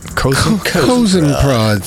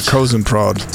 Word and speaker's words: Kosen Prod: Kosen Prod. Yeah. – It's like cattle prod, but Kosen Prod.